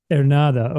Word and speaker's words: Ernada: How do you pronounce your name? Is it Ernada or is the accent on the second A or? – Ernada. – Ernada. Okay. All right. So I was always pronouncing it incorrectly then. I thought it was Ernada.